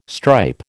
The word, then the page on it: stripe: American English